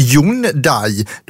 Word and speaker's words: Hyundai: Men hur det ska uttalas när de säger det på rätt sätt, vilket är Hyundai, då låter ju det som att nu uttalar du helt fel här.